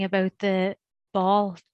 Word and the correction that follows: Baul: Is that how you say it? Ball tradition? I don't know if I'm pronouncing the word right. Ball. – Bowl. – Bowl. – Bowl tradition.